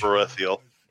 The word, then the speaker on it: Sparethiel: She's one of the two, and she just calls out and she yells out in something in Sparethiel, I think is how you pronounce that, the elven language. – Sparethiel. Yeah. Yes.